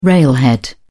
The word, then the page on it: railhead: British English